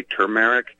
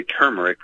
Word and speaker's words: turmeric: Some people say turmeric, put the emphasis on the first. Now, that's very different than everybody running around selling and screaming, turmeric, tumor. There's no tumors, there's no tombs, there's no mausoleums, there's no, it's not T-U-M, it's T-U-R.